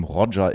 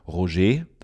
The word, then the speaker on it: Roger: Und das hat zur Folge, dass die Aussprache seines Vornamens eben Roger ist und nicht, wie man denken könnte, in der Schweiz, nicht diese äh, französische Variante Roger.